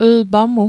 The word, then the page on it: lpa mu: l'pa mu